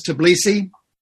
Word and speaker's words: Tbilisi: In the country of Georgia, how do you say this, Tbilisi? – Yes.